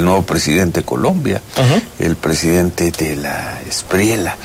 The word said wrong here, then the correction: Espriella: Espriella, ¿verdad? Se pronuncia con la doble L, quien apenas el viernes, me parece, asumió la presidencia de Colombia.